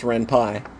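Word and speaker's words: Ren'Py: That's why it's pronounced Renpy.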